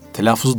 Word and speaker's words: telaffuz: Telaffuzu doğru ettin mi? Doğru telaffuz ettin mi hocam? – F'yi çift okuyoruz. – Telaffuz. – Evet efendim.